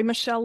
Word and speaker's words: Michele: So one, I'm on LinkedIn with my name, Lori Michele Leavitt, and Michele is spelled with one L.